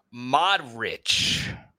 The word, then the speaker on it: Modric: I pronounced his name wrong. It's pronounced Modric.